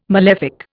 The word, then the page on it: malefic: Indian